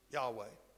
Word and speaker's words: Yahweh: Yahweh would probably be a closer pronunciation of it. Yahweh.